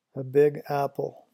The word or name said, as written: a big apple